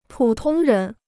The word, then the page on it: 普通人: pǔ tōng rén